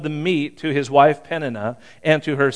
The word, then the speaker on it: Peninnah: And Penaniah, I'm not probably saying that right. You know, you should always practice these before you do. And I just read, as I read it, I thought, that doesn't sound like Penaniah. Peninnah. I've always said her name wrong.